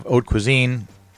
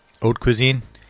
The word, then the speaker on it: haute cuisine: Then you have haute cuisine. How is that? How do you say that? Haute cuisine.